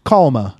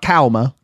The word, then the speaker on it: Kalmah: I think I put a little too much emphasis on one of those syllables there in Kalmah. Kalmah, not Kalmah.